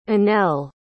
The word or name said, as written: inell